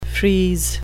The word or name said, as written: frieze